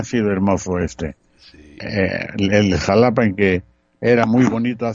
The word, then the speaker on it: Xalapa: Con todo y que un gobernador que tuvimos en los 70 le cambió y antes se escribía con J, Jalapa, y ahora se escribe con X. – Sí, Xalapa. La X y la J.